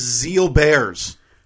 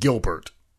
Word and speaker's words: Gilbert: WTF is Zeal Bears. – All right. Well, it looks like Gilbert, but I'm just learning right now that it is pronounced Zeal Bear.